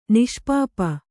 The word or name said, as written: ನಿಷ್ಪಾಪಿ